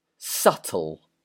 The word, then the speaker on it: subtle: S-U-B-T-L-E. So, this word is subtle. Subtle. So, with this word, the B is silent, so do not say subtle or subtle, it's just subtle. Number two, H-E-I-G-H-T.